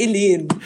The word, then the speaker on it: Elen: Nee, Elen. Also mit Betonung auf dem zweiten E und alles äh, ganz, ganz viel E, wie das Alphabet E.